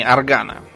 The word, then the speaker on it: Арган: Вот, например, э, была такая специальная, э, рассчитанная на китовое масло э, лампа швейцарского врача Аграна, а, вернее, вернее, Аргана, да, Арган через А пишется.